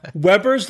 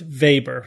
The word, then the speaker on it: Weber: It's actually pronounced Weber, so I'll pronounce it correctly from this point forward. It's W-E-B-E-R, but it's pronounced Weber.